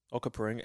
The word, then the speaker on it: Onkaparinga: Onkaparinga. And that's exactly how it's spelt. I copied letter from letter.